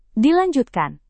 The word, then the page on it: dilanjutkan: indonesian